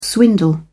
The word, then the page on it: swindle: British English